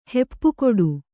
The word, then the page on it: ಹೆಪ್ಪುಗೊಡು: heppugoḍu